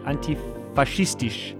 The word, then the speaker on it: antifaschistisch: It's antifaschist. Antifaschistisch. It's antifaschistisch. – Antifaschistisch.